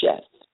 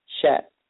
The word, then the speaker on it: Sheth: Seth's name in the Hebrew is pronounced Sheth, for God appointed. Sheth.